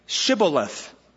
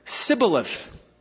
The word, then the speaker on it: Shibboleth: And he would say, if he's an Ephraimite, Sibboleth, for he couldn't pronounce it right.